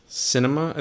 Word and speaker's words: Sinema: senator, um, Sinema, I think is how you say her name.